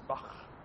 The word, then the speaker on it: Bach: Yes, it's pronounced as Bach.